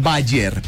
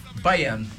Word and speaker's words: Bayern: Pero me gusta porque él siendo alemán pronuncia como realmente se pronuncia el Bayern. Que nosotros aquí en esta parte del mundo lo decimos Bayern. ¿Pero cómo se pronuncia ese equipo? – Bayern.